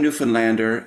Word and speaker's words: Newfoundland: One Newfoundlander in the Broadway company, she taught us that in school, the kids are instructed to say, understand Newfoundland, which both have third syllable stress, and they both have that ah sound for the land and stand.